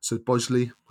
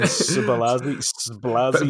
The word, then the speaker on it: Szoboszlai: I can't pronounce his name, but Haaland's teammate at Salzburg, Szoboszlai. Badly mispronounced that name. – Yeah, he's a really tough one. Szoboszlai.